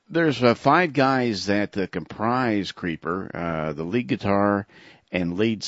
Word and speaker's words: Creepor: Okay, band number four is a band called Creeper, which is pronounced Creeper, but spelled C-R-E-E-P-O-R.